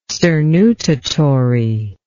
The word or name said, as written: sternutatory